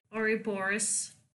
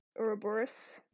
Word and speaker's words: Ouroboros: And the bone carver will only help if they bring back an artifact to him called I pronounce it Ouroboros. – Ouroboros.